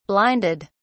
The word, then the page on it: blinded: British English